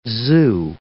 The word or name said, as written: zoo